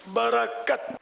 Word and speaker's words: برکت: صحیح تلفظ ہے برکت برکت.